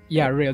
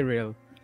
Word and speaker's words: Rail: Yeah, that's Rail. Just say Rail, like Rail.